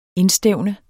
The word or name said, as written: indstævne